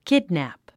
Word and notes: kidnap: kídnæp; キドナプ

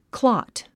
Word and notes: clot: klɑ’t; クロット

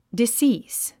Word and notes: decease: disíːs; ディスィース

